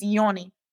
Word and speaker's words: Jonny: it's spelled J O N N Y, right? – Yes, but it's Yoni.